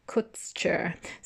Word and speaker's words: Kutscher: Kutscher. So K-U-T-S-C-H-E-R, Volker Kutscher.